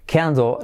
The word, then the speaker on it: candle: Tenéis un vídeo de la letra clave L donde explicamos que al final de palabra casi no se pronuncia candle. Sin embargo, en medio y al principio sí que sería little family, ¿Sí? Se pronuncia un poquito más.